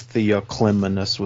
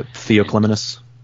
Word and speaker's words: Theoclymenus: I was going to say Fagles has a pronunciation glossary. He supplies it as Theoclymenus with the emphasis on the cli. So Theoclymenus.